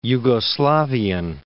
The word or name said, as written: Yugoslavian